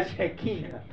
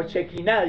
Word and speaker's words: Shekina: La chequina, Jehová. No la Shakira, ¿no? La Shekina. O Shekina, dicen otros. Pronuncian otros.